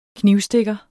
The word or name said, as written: knivstikker